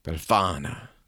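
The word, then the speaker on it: Befana: She's called Belafana, if I'm saying that right, but she's an old woman. – Spell it. – B-E-L-F-A-N-A. – Belafana. – Yeah, that's better.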